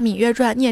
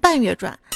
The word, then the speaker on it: 芈月传: 他留言说：“ 白在清华待那么长时间了，一直把《芈月传》念成《半月传》，这下又被厨师长笑话了。”